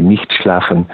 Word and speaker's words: nicht schlafen: I can pronounce this in the German way, nicht schlafen, which means do not sleep.